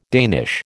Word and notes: Danish: American English

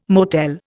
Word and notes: motel: Indian

